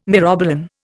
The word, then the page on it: myrobalan: Indian